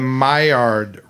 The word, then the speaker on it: Maillard: Then the Maillard reaction. Am I pronouncing that correctly? – Yes.